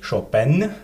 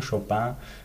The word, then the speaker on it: Chopin: Eigentlich Chopin, das wird nach der französischen Maniere ausgesprochen, Chopin.